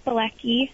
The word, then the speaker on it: Bilecki: Yeah, you said it right. It's Bilecki.